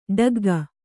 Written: ಠಕ್ಕಿಕ್ಕು

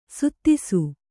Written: ಸದಸ್ಸು

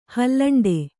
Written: ಹಾಲುಂಡೆ

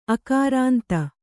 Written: ಆಕ್ರಾಂತೆ

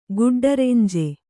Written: ಗುಡ್ಡರೆಂಜೆ

